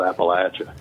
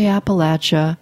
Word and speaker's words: Appalachia: You say Appalachia. Most everyone else here in my region says Appalachia. Tell us a little bit about that. – Well, you will hear that pronunciation change as you go farther north. Most people where I live, which is in southern Appalachia, pronounce it the way I just did, but I certainly won't be dogmatic about that at all.